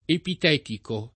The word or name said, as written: epitetico